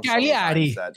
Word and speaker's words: Cagliari: Cagliari.